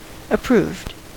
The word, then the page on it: approved: American English